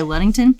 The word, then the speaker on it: Ludington: So, and he wanted us to share what's going on in Ludington, Michigan. I hope I'm pronouncing that correctly. – That's right. Yeah. – Okay, Ludington.